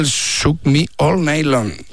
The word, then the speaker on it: Shook Me All Night Long: Me cuesta el Shook Me All Night Long.